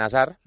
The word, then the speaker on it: Hazard: Él es belga, se pronuncia, es Eden Hazard. Esta es la forma correcta de decirlo. Además, a mí me han comentado algún compañero que tampoco se pronuncia ni la H, por supuesto, ni la D final, casi.